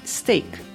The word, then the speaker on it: steak: Mas quando queremos falar bife, a pronúncia correta é steak.